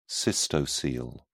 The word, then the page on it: cystocele: British English